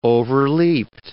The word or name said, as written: overleapt